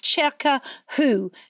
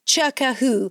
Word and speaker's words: Circawho: It's called Chircahoo, C-I-R-C-A-W-H-O, Chircahoo.